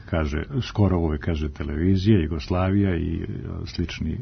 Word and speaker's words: Jugoslavija: Ja privatno kažem televizija, Jugoslavija, međutim po normama našeg jezika i kad sam pred mikrofonom moram da kažem televizija, Jugoslavija i postoje pravila jezička kod tih složenica, kad je silazni akcenat na kraju, televizija, silazni, onda se akcenat prebacuje napred, televizija ili Jugoslavija.